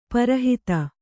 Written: ಪೌರೋಹಿತ

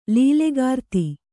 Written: ಲೀಲೆಗಾರ್ತಿ

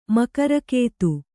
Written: ಮಕರಕೇತು

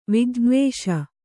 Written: ವಿಜ್ಞವೇಷ

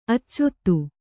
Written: ಆಚದು

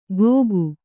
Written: ಘೋಕು

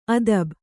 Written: ಆದಾಬು

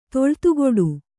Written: ತೊಳ್ತುಗೊಡು

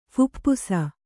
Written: ಫುಪ್ಪುಸ

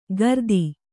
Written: ಕಾರ್ತಿ

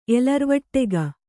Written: ಎಲರ್ವಟ್ಟೆಗ

